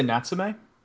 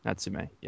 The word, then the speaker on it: Natsume: I'd say Natsume. – Natsume, yeah.